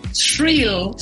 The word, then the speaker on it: Shrill: ¿Cómo se llama? ¿Cheryl? Cheryl. Shrill. Shrill. H-R-I-L-L.